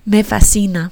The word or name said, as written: me fascina